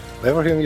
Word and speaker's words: Leverhulme: Marc's independent academic career has been fueled by prestigious Leverhulme. Oh my gosh, this one I don't know how to pronounce. You have to help me. Leverhulme? – Leverhulme, you've got your two for two.